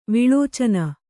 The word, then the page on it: ವಿಲೋಚನ: Kannada